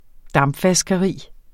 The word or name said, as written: dampvaskeri